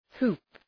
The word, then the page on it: hoop: hu:p